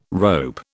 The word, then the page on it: rope: British English